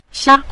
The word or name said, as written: xia5